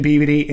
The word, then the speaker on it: Peabody: And it's pronounced Peabody, not Peabody.